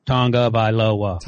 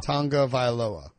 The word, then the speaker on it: Tagovailoa: Tagovailoa. – Tagovailoa.